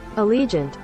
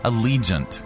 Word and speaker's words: Allegiant: How do you pronounce this? Let's get a pronunciation key. I guess it's Allegiant. How to pronounce. There we go. Yeah, that's our Pronouncify lady. – Allegiant. – Oh, Allegiant? Then why is there an extra I in there? Allegiant. Okay, great.